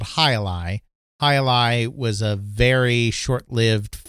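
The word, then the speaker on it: jai alai: It's J-A-I hyphen A-L-A-I, but it's pronounced Hi-Li. So they added the squash game as Hi-Li, released it as the Telstar Alpha, and released it for $40.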